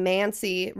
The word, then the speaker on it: mancy: And then mancy rhymes with Nancy.